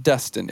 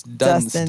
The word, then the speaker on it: Dunston: So it's a lot to have to go back and find VHS cassettes of Dunston Checks In, which is our movie for today. – Yeah. – We have 3 more episodes left in season one. It's not Dustin, it's Dunston.